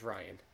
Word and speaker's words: Rian: Is it pronounced Ryan? Is that correct? – Yeah. Yes, correct. – Ryan Johnson. I just don't know. I just didn't know the answer to that. It's the Irish spelling, but yes, that is Ryan.